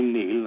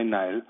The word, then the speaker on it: Niall: In different parts of Ireland, my first name, Niall, is pronounced in different ways. Some Irish people say Neil and some say Niall, but my family and I have always pronounced it Niall, Niall Leonard.